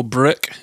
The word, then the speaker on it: Brokk: And you do meet Sindri and Brokk, or Brokk, if you're pronouncing it as an "ur," these wonderful dwarf brothers that you meet throughout your adventure, who are blacksmiths and real characters.